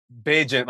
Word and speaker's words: Bagent: Tyson Bagent or Badgett? – Bagent, like agent. – Yeah, you said it right.